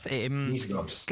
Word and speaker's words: NIS2: Directiva NIS2, creo que lo he dicho bien, ¿verdad? – NIS2. NIS2. – Eso es.